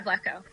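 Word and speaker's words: Vlatko: I'm sure I'm going to butcher his name, but Vladko, right? – Yeah, Vladko.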